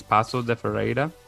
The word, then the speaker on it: Paços de Ferreira: You finished third with, I don't want to mispronounce it, but I think it's Paços de Ferreira. – Yeah, that's it. Right, yeah, it's good, yeah.